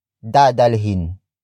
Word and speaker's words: dadalhin: Saan nila dadalhin ang bahay? Saan nila dadalhin ang bahay? Saan nila dadalhin ang bahay? It means, where will they carry the house? Word for word, it's saan nila dadalhin ang bahay?